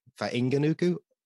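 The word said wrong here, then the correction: Fainga'anuku: So the trick with the Maori names is, if you see an NG, that's the start of a new syllable. So it's Fainga-anuku. So Fainga-anuku.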